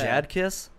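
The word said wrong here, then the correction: Jadakiss: Yeah, Jad Did Kiss. You mean Jada Kiss? – Yeah.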